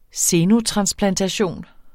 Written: xenotransplantation

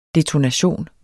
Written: detonation

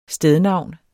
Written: stednavn

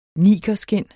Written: nigerskind

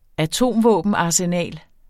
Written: atomvåbenarsenal

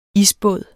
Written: isbåd

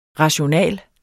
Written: rationale

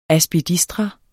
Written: aspidistra

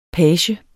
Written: PAGEN

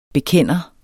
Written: bekender

